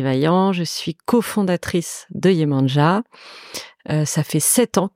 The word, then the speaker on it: Yemanja: Yemania, si je le prononce bien, j'espère. – Yemanja. – Yemanja, voilà. Mais, au Brésil, au début. au Brésil, ils, le disent plus comme tu l'as dit.